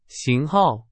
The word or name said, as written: 型号